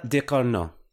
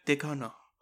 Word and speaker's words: Ducournau: Julia Ducournau. – Julia Ducournau.